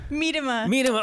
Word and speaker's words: Miedema: Miedema.